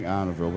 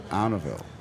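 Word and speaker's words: Arnaudville: There's a special way of saying Arneville, because it's spelled A-R-N-A-U-D. – Like Arno. Like, yeah. – Like Arno. – But if you come from there, you say Arneville.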